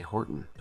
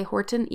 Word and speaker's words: Horton: Well, I like how you pronounce our last name, because I always swallow the last syllable and just say Horton, but you... put a lot of emphasis on the T.